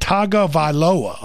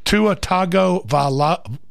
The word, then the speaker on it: Tagovailoa: That is Tua, and you pronounce it Tagovailoa, okay? Tua Tagovailoa. That's the way it's pronounced.